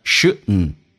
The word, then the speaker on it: shouldn't: 那么这边的关键点叫 shouldn't 跟 have，它弱化现象一定要知道，因为 shouldn't dn 组合 d 可以不用发音，气流阻塞一下变成 shouldn't，然后跟 have 它有一个弱化现象，也就意味着 shouldn't 跟 have 说成 shouldn't 或者 shouldn't，那个 v 可发可不发，我们会说成 shouldn't。